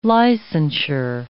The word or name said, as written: licensure